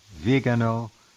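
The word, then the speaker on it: Viganò: And I keep pushing him, hoping that there will be a statement made by maybe Father Kramer, Archbishop Vigano, which is how he pronounces it, and others, to say that the church is now split.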